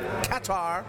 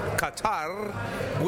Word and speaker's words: Qatar: The best part of coming to Qatar for me has been the realizing that there's no one way to pronounce Qatar. Everybody, right? I mean, we've heard it pronounced Qatar, Qatar, Qatar, with or without the rolled R.